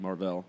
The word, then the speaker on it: Mar-Vell: And the whole reason they called him Marvel was because they mispronounced his name. It's supposed to be Mar-Vell.